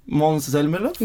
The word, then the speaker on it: Måns Zelmerlöw: Last time was 2015 with Heroes of sorry for the pronunciation, Måns Zelmerlöw. – No, that's good. – Oh, thank you.